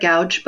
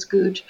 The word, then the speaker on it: Goudge: So the last name is spelled Goudge. So it looks like looks like gouge, but it's British. She's a British author, and the British always pronounce everything funny, so it's Gooch.